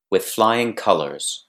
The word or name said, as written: with flying colors